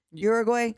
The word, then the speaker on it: Uruguay: So the first World Cup was in 1930, and it was hosted by, I'm going to butcher the name, Uruguay? Uruguay? – That's correct. – Uruguay.